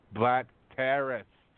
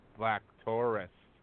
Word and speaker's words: Black Taurus: First of all, I'm going to make you pronounce it properly. Try again. – Black Terrorist. – No. – Black Taurus. – Almost. Put a little stank on it. A little ass on it.